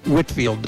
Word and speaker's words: Whitfield: P-A-U-L, Paul, Whitfield, W-H-I-T-F-I-E-L-D.